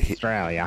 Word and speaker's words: Australia: Well, you'll get Australia. – Hit. – I don't know why I can't say it right. – Australia. Drop the A-U. Say S-T-R-A-Y-L-A. Australia.